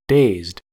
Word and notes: dazed: American English

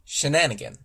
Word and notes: shenanigan: American English

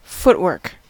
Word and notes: footwork: American English

